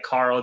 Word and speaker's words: Carl: And you can find me on Twitter at Carl Zha. That's just my name. So Carl spelled with a C, C-A-R-L.